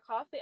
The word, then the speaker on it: Coffy: But you also have another, on other social channels, you spell it C-O-F-F-E-Y. So exactly. Where can people reach you? – Okay. So my LinkedIn is Rebecca Sarah Coffey. I'll spell that R-E-B-E-C-C-A Sarah S-A-R-A Coffey C-O-F-F-Y.